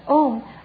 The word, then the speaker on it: Om: Om, actually Om consists of three syllables, A-U-Ma.